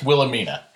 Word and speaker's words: Wilhelmina: You don't pronounce the H as heavily as you do. It's just Wilhelmina. – Wilhelmina. – Wilhelmina. You always say the H.